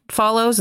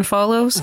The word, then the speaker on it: Follows: And it's kind of her and I always pair her with the Anne of Green Gables actress because they both have weird pronunciations of their names, because like, she's Megan Follows instead of Megan Follows.